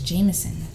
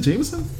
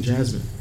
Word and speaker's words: JMSN: Is it Jameson? – I think that's how you pronounce it. – I was always saying Jasmine.